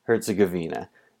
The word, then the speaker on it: Herzegovina: First, Herzegovina is pronounced Herzegovina. Second, I do love me a good parody song.